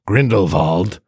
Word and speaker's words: Grindelwald: So today we are spoiling Fantastic Beasts, The Crimes of Grindelwald, or as it's pronounced in the movie, Grindelwald.